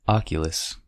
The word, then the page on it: oculus: American English